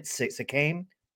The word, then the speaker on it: Siakam: Siakam.